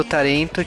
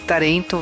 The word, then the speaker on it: tarento: É, eu, pelo que a gente pesquisou, Tarento, tipo assim, tarento veio da, veio da palavra talent em inglês, ó, oh, né? A pronúncia japonesa para talent, né? Então, cebolinha ao inverso, né? Tarento.